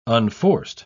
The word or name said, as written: unforced